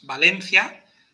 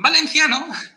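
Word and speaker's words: València: Decimos, bueno, de hecho, decimos Valencia, y eso en castellano son tres sílabas. Valencia. Pero curiosamente, en valenciano, en catalán, en élfico, no hacen diptongo, separan dos sílabas. Por eso es Valencia. Son cuatro sílabas y por eso entonces en valenciano es esdrújula. Y por eso lleva tilde en valenciano.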